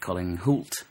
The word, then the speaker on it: Colin Hoult: And I loved the piece that you did with Colin Hoult. Am I pronouncing that right? – Yeah. Yeah.